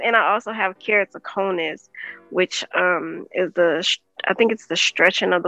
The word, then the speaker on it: Keratoconus: Keratoconus.